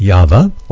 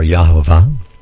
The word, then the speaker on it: Yahweh: If you look in the Hebrew, in the underlying Hebrew, it says YHVH, pronounced Yahweh or Yahovah.